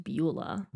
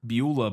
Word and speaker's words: Beulah: Uh, Thomas Mitchell, Henry Travers, uh, Balua, Bula Bondi, Ward Bond, Frank Faylen, and Gloria Grahame I believe that's pronounced Beulah. – Beulah Bondi. There you go.